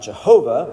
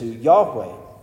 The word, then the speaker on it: Yahweh: It's not Jehovah, but it's closer to Yahweh.